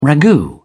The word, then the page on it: ragout: British English